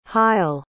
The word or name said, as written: hyle